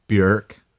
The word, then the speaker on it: Björk: And I've heard that, well, not only have I heard, but I also checked this on the internet because of the umlaut, right? It's like pronounced Björk.